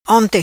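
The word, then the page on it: Onte: patua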